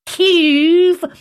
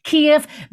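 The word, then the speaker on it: Kyiv: That's the correct pronunciation. It is not Kiev, as the BBC would have you believe. It is, in fact, Kiev.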